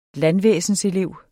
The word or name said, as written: landvæsenselev